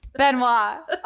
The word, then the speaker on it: Benoit: But he can't be Chris Benoit. Benoit. I did it wrong. Edit that out. No. Benoit. Oh my God. It's because of how it's spelled.